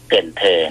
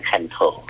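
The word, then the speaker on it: Cần Thơ: เกินเธอถ้าอ่านตามภาษาอังกฤษจะอ่านว่าคันโธถ้าอ่านตามภาษาอังกฤษจะอ่านว่าคันโธ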